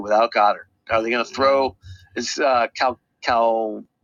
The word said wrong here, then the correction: Calcaterra: What's his name? – Calcaterra. – Calcaterra, is he? – I'm the one that's usually butchering their names.